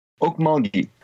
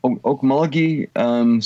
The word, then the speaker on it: Okmulgee: Okmulgee. Okmulgee, south of Tulsa.